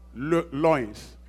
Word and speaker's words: loins: "Stand therefore, having your Lions? How do you pronounce that? Loins. Loins, right? Yeah.